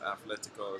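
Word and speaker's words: Atlético: And we've been pretty impressed with Atletico Paranaense, and yes, it is meant to be pronounced Atletico as well.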